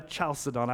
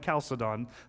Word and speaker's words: Chalcedon: Some people call it Chalcedon, but I'm not a Chalcedon. I'm a Chalcedon. That's the way I pronounce it.